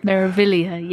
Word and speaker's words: Meraviglia: Well, you know, I'm speaking of MSC, I'm going to be taking an MSC cruise later in the year, Meraviglia. I guess I'm saying it right. – Meraviglia, yes.